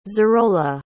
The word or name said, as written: zarola